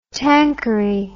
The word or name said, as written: tanqueray